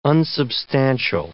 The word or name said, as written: unsubstantial